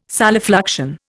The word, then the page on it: solifluction: Indian